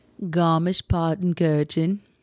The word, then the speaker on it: Garmisch-Partenkirchen: Garmisch-Partenkirchen. – That's possibly how you say it.